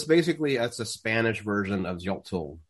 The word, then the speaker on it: Xochitl: Xochitl. – What is it? – Hold on. Xoch. – Itl. – Xochitl. No, Xochitl.